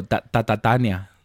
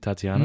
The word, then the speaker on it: Tatiana: My mom didn't know how to pronounce that name for years. – What, Tatiana? – She would go Tatania. Tatania. – No.